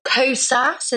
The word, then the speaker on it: Kosas: Kosas, is that how you say it? – Yeah.